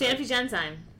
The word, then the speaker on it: Sanofi Genzyme: Sanofi Genzyme.